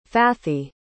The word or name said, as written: fathi